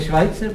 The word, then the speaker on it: Schweitzer: No to se vyslovuje Schweitzer. – Co? – To je Švajcer právě.